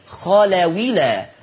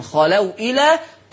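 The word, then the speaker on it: خلوا إلى: مكسورة فتصير خلاويلا، خلاويلا، هكذا. مفيش نطق للهمزة، اوعوا انتوا بتعملوا نقل حد يمضى الهمزة خلاص، ما لما نيجي نعمل حكم النقل الهمزة بتتشال، تحذف الهمزة للتخفيف وبنقل الحركة بتاعتها للساكن، خلاويلا تنطق خلاويلا.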